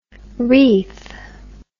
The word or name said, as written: Reith